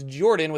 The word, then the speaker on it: Jorphdan: Jordan here. Welcome to the Saturday Morning D&D Show. My name is Jordan with a silent PH in the middle, and I am joined always by my wonderful co-host, Sir Lucian, over at Sir Lucian Gaming.